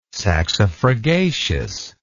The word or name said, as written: saxifragaceous